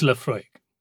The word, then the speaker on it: Laphroaig: It's Laphroaig.